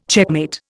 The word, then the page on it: checkmate: Indian